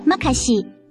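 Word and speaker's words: makasih: 一年级的多谢是马卡西，读音甲台语马卡真细真共款。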